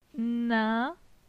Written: Nin

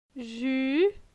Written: Ju

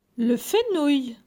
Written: Le fenouil

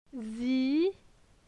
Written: Zi

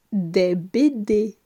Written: Des bd

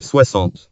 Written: 60

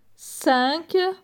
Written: 5

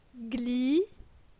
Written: Gli

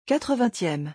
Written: 80eme

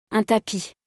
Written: un tapis

